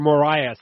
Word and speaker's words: Moraes: Uh, Marlon Moraes, or Morias, actually, if you want to say it like uh, us North Americans.